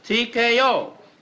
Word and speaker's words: TKO: Williams bags crown by Taco in eighth. – Taco? – Yeah, Taco. T-K-O. How else would you pronounce it? – That's how you pronounce it. T-K-O. It means technical knockout.